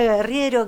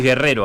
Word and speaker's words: Guerriero: Porque a veces pronuncio mal el apellido y no quería decirlo bien. Es Leila Guerriero, que es una... – Decís Guerrero a veces.